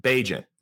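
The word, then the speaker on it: Bagent: Tyson Bagnet. – Bajent. Bajent is how you say it.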